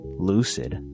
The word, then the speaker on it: Loocid: Sayin' Some, Lucid, that's spelled L-O-O-C-I-D.